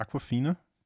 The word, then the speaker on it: Awkwafina: Heißt nicht Awkwafina? Diese Asiatin, asiatische Schauspielerin. – Ich glaube, du hast das richtig ausgesprochen. Ja.